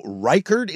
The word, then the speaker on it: Reichard: And Vikings kicker Will Reichard, is that how you say his name? – Yes.